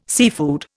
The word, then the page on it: seafood: Indian